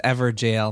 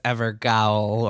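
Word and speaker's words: Evergaol: By the way, when Kirk says Everjail, most of us out there are going to read that as Evergowl or Evergowl. – I'm being sure to pronounce it correctly. – That's how I pronounce it. Yeah, I thought it was Evergowl, but Everjail makes it sound like it's spelled Everjail, like J-A-I-L. Right. It's not. Mm-hmm. – It is not spelled that way, but it is pronounced that way.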